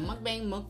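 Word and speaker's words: mukbang (먹방): How you say it? Mukbang? – It's mukbang. It's a mukbang.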